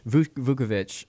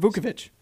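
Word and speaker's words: Vučević: Yeah, they traded Nikola, and I don't want to butcher his last name, so let's just say he is Vukovic. – Vukovic. – It's not that hard, Vukovic.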